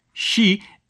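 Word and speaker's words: shi (四): Because in Japanese, the word for four, shi, is pronounced the same as the word for death.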